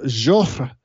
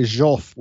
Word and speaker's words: Joffre: In the meantime, the supreme commander of the French army, Marshal Joseph Joffre, and it's J-O-F-F-R-E, and you pronounce the R-E just a little bit. Some people say Joffre without even doing the R-E, but it's hard for an English speaker, but Joffre.